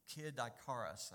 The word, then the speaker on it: Kid Icarus: Kid Icarus, I think it's pronounced.